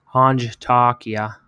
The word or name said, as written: aanchtaakia